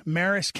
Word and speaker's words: Maries: He's a rancher in Missouri, way out in the country, Marist County. That's spelled like Maries or M-A-R-I-E-S, Marist County, beautiful county in sort of western central Missouri.